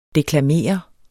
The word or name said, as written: deklamere